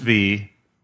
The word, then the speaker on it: Viy: It's pronounced V, but it's spelled V-I-Y.